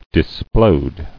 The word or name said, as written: Disploding